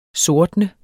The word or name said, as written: sortne